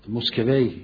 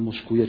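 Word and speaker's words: مسکویه: کسانی بشینن بحث بکنن از فارابی تا خواجه نصیر تا بوعلی تا موسکوی یا موسکویه، چون هر دو تلفظ شده، یکیش تلفظ عربی یکی فارسیشه، غزالی، خواجه نصیر، ابن خلدون، ابن باجه، ابن توفیل، ابن رشد و دیگران و دیگران که در این باب اینا بحث کردن.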